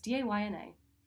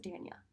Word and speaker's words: Dana: So Day by Dana was one, everyone pronounces my name wrong, okay? It's Dayna. Everyone says Dania. It can't get any easier. Like, it literally is Day Na, okay?